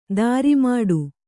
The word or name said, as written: ದ್ರಾಮಿಡಿ